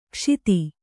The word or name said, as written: ಕುಶದ